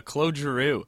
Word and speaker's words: Claude Giroux: But I'm going to do a better job at pronouncing his name than the people who drafted him originally, but it's Claude Giroux. Do you remember that one when he got drafted? – Yeah, Claude. – Space Cadet. Grow. – Space Cadet. – But Littering him. – Yeah, Claude Giroux is someone who's kind of,